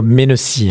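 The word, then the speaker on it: Mennecy: Le trafic est perturbé entre Juvisy et Malesherbes via Rissangis en raison d'un train en panne à Menessy ou à Menessy. Oui, c'est plutôt Menessy. Hein. C'est comme ça que ça se prononce.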